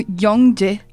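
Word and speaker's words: Yongje (영혜): Até para ela aproveitar e dizer qual que é o nome certo, a pronúncia certa é, da, da protagonista do livro. É, quem é? Yongye? É assim que se fala? – É, é eu, eu falo no Yongje.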